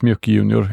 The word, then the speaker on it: MJUC Junior: Så att jag var väldigt glad när du kom med Mjuk Junior, heter den så? – Ja, precis. Mjuk Junior. Jag tror det är så det uttalas.